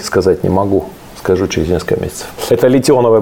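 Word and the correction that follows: литий-ионные: батареи. Литионные, на самом деле, правильно говорить.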